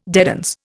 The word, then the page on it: dedans: Indian